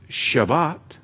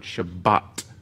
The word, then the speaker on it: Shabbat: The root of the Hebrew word Shabbat or Shabbos, depending if you how you pronounce it in Israel, it'd be Shabbos. But over here, you know, in more of an Ashkenazi pronunciation would Shabbat, right? But in Israel, over in the land, it's Shabbos, Shabbos, right?